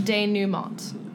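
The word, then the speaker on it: dénouement: It is French. Denouement. – Denouement. – French. – I don't know why you're looking at me. I cannot help you. – And she gave you a D by pronouncing one of the letters. That shouldn't be there. – Right. Yeah. I know that. I expected there was a silent T, but I'm just, everything up until that point is very scary.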